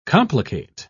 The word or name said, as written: complicate